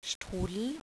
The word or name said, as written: strudel